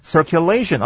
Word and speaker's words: circulation: circulation 啊 circulation 那个 U 啊念作耶的声音就好了，所以整个字呢念作 circulation。